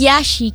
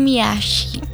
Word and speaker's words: Yashi: So, paano ba dapat siya binibigkas? – Yashi, Kim Yashi.